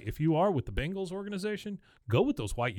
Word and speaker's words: Bengals: learn how to say the word Bengals, not Bangles.